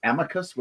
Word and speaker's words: amicus: I think the accepted is amicus, with the emphasis on the first syllable, as we often say.